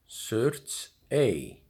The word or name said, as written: Surtsey